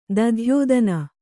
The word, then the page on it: ದಧ್ಯೋದನ: kannada